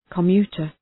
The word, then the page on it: commuter: kə’mju:tər